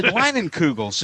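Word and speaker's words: Leinenkugel's: I said, Leinenkugels,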